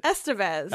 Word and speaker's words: Estevez: Estevez. – How do you say it? – Estevez.